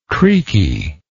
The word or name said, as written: creakiness